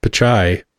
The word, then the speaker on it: Pichai: Sundar Pichai.